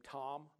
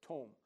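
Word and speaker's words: Tom (תם): The word integrity, it's the Hebrew word looks like the word, you know, the name Tom, but it's Tom. So T-O-M, pronounced Tom.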